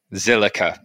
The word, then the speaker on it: Zilliqa: And of course, last but not least, making a debut today is who's co-founder of, I never know how to say this, Zilla? Is it just Zilla? The Q always confuses me. – Zillica. – There we go.